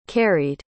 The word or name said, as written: carried